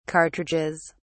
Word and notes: cartridges: British English

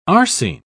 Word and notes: arsine: British English

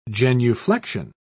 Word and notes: genuflexion: British English

